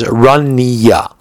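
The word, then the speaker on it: Ranya (רניא): Because the way that he wrote the Resh Aleph Nun Yud Yud Aleph is usually written as Run, is usually pronounced as Ranya, with a chirik under the nun, an ethan under the nun, and a kamatz under the yud, Ranya. So if that's what he meant, then fine.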